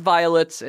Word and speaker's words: Vylts: His band's name is Violets, and it's spelled V-Y-L-T-S.